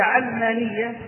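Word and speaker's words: عَلمانية: ولذلك بعض المتفلسفة يطرحوا يقولون تسمى علمانية وليست علمانية، ويريد أن يجعلها بكسر العين نسبة إلى العلم، وليس الأمر كذلك، فهي علمانية صحة النطق بها نسبة إلى العالم المادي، نسبة إلى هذا العالم، نسبة إلى الدنيا، نسبة إلى المادة، إلى العالم المشاهد المحسوس.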